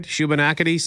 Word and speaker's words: Shubenacadie: Did I say that right? Shubenacadie, Sam? – You did a great job.